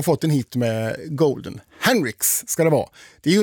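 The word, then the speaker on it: Huntr/x: Jag sa ju att det här var Golden med Hunter X. Nej, så ska man inte uttala det där namnet, har jag lärt mig nu. Utan det här är ju, det ska vara Henrix ska det uttalas.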